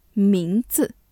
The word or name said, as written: 名字